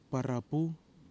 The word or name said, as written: PARAPU